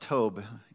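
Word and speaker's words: tob (טוב): The word is tob. I don't know the Greek letter, I mean the Hebrew letters, but it's pronounced tob. You would phonetically spell it out, T-O-W-B.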